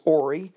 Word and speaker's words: Horry: The names ranged from Ory, even though it's spelled H-O-R-R-Y, but it's pronounced as Ory, Hugie, Lawrence, Linaud, Manigo, and Postel.